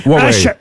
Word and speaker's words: Huawei: Comment on prononce? – Huawei. Huawei. – Ah ben voyez. – Huawei. – Qui c'est qui avait raison? C'est bien Huawei.